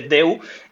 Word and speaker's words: Daewoo: Y cuando ya te dicen que el Daewoo de toda la vida no se pronuncia Daewoo, sino que se dice Deu, ya es como, ¿por qué? No, se escribe totalmente diferente. – ¿Cómo se pronuncia Daewoo? – Daewoo es Deu. – Deu. – Bueno, sí, al final la cosa es que esa AE que vemos es una E para ellos.